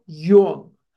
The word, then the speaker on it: Jung: named Carl Jung. J-U-N-G is pronounced Jung, not Jung, not Jung, but Jung. And he goes to Switzerland to see Carl Jung.